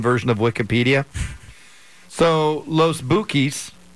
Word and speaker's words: Bukis: It's pronounced Bukis, kind of like the Black Keys, but the Bukis.